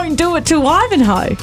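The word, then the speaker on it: Wivenhoe: But you still pronounce the H in Wivenho. – The way, just off the top of my head, the pronunciation is Wivenho.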